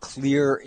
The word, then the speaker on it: Xlear: He is the CEO of Clear Inc. That's spelled X-L-E-A-R Incorporated.